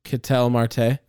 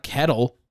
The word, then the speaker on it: Ketel: Ketel Marte. – Is that how you say it? – It's not kettle. – I've always heard people say kettle.